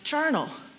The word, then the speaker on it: Charnel: My middle name, Charnel, in French is pronounced charnel, which means pertaining to the house of the dead.